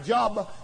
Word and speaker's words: Job: That's J-O-B, looks like Job, but it's pronounced Job. J-O-B in your Bible.